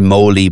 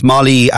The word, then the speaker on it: Molly: Yeah, there's a bit of history to Molly, and some people pronounce it as maybe Molly, but it's Molly after Molly Bloom from Ulysses.